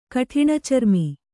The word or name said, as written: ಕಠಿಣಚರ್ಮಿ